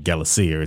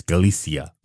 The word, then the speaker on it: Galicia: I said Galicia. It's Galicia.